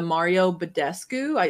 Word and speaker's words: Badescu: And, I found the Mario Badescu.